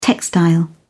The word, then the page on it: textile: British English